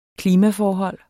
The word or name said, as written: klimaforhold